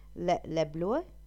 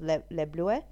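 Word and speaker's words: Leblouh: Okay, I'm not too sure how to say this word. Lebloue? It's L-E-B-L-O-U-H. So I'm going to go with Lebloue.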